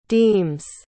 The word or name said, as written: deems